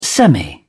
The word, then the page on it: semi: British English